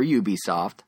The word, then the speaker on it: Ubisoft: Ubisoft, or Ubisoft. I recently learned both pronunciations are correct.